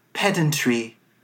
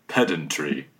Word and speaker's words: pedantry: Pedantry. – And here's the U.K. pronunciation. Pedantry. – Ah. So there's not a long E to be found.